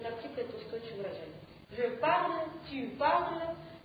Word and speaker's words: parle: Значит, в форме «я» добавляется буква «э», которая не читается. Соответственно, «я говорю по-французски», будет Михаил. – Je parle français.